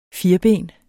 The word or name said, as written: firben